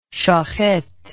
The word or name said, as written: shohet